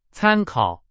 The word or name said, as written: 参考